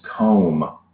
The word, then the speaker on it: comb: C-O-M-B. – You want me to start the... – B is silent. – Yeah, there's a silent B at the end of comb.